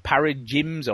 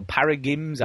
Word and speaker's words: paradigm: But I've got my head around this system of, I don't even know how you pronounce it, paradigms or paragims.